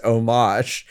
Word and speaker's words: homage: Now, Jordan has said, that's pronounced homage, but I think he wants me to say homage, but I don't homage? Know. – Get a hard H in there? – Yeah, hard H like the Brits do.